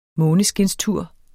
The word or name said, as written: måneskinstur